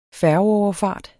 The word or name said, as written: færgeoverfart